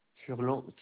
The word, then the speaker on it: Furlan: Furlan Kevin. – Alors, ça s'écrit comment, s'il vous plaît? – F-U-R-L-A-N. Mmh. Et Kevin K-E-V-I-N. – Comment? – Et Kevin K-E-V-I-N.